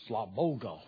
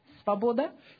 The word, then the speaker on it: свобода: Свобода.